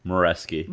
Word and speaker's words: Moreski: Moreski.